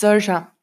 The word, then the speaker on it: Saoirse: Das ist Saoirse Ronan.